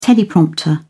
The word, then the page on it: teleprompter: British English